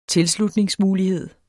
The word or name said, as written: tilslutningsmulighed